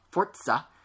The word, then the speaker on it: Forza: There was no no Killzone Shadowfall for PS4, there's no Forza 5, or apparently, as it's properly pronounced, Forza, because it's Italian or something,